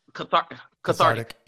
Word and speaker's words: cathartic: So it was like, it was carthitic. It was cathartic.